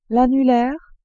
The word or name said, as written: annulaire